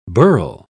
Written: burl

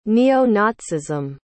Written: neo-nazism

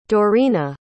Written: Doreena

